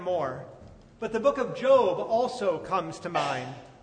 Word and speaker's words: Job: And for those who are newer to the faith, Job is an Old Testament biblical character whose name looks like our English word job, but is pronounced Job.